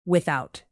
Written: without

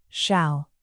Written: shall

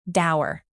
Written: dour

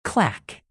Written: clack

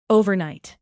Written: overnight

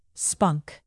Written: spunk

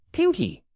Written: pinky

